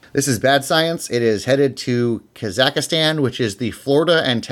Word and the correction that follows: Kazakhstan: It's Kazakhstan. Why do you add an extra syllable every time? – My wife pronounced it like that.